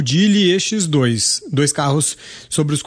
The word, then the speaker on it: Geely: Geely.